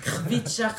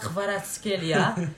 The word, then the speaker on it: Kvaratskhelia: Kvitschak Kvaratskhelia,